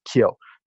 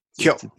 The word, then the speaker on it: KYO: If we were to say it properly, it's KYO. – KYO, yeah. – Very fast like very that.